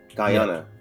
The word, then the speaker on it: Guyana: Is it Guyana? – Guyana.